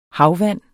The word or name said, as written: havvand